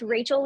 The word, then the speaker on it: Rachael: And it's Rachael Leigh Photos. Rachael is spelled like Michael. So it's R-A-C-H-A-E-L. And then Leigh is L-E-I-G-H.